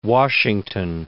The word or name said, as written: Washington